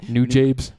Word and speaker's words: Nujabes: Is that how you say it? Yeah. I didn't want to say it. – Nujabes. – Nujabes.